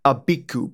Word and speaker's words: Abiku: Our second spirit today is Abiku, pronounced Ab Ik U alternate titles, no other names, but often mixed up with other spirits, namely Ogbanje, and we'll cover that one later.